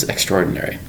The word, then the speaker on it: extraordinary: The word is extraordinary. It's spelled E-X. T-R-A-O-R-D-I-N-A-R-Y. Five syllables for the word extraordinary.